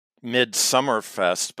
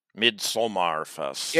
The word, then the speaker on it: Midsommarfest: So you pronounced that Midsummerfest, but you know, it's a Swedish thing, isn't it? – Yeah, but... – And it looks like Somar. Midsommarfest.